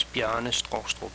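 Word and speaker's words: Bjarne Stroustrup: The proper Danish pronunciation of my name is Bjarne Strustrup. Native English speakers usually get Bjarne right, but I often have to say Strustrup or Straustrup to be understood.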